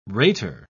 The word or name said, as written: rater